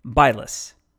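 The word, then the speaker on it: Bylas: or as we pronounce the settlement along US 70 named after him today, Bylus.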